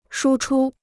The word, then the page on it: 输出: shū chū